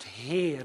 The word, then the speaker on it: Heir: Jongens en meisjes, dat woord Heer, als je nou meegelezen hebt in je Bijbel, en ik hoop dat je het altijd doet, dan staat daar Heer, H-E-I-R. En dat spreek je uit Heer. Dat is een oud woord, dat betekent leger.